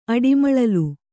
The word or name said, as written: ಅಡಿಮಳಲು